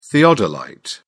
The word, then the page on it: theodolite: British English